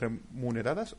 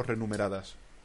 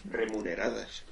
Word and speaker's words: remuneradas: Y bueno, eh, deberíamos hablar de las cuentas remuneradas o renumeradas. ¿Cómo se dice? – remuneradas. – Remuneradas, ¿no? De remunerar.